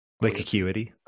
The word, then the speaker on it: Lake Acuity: Lake Acuity. – That's how you pronounce it.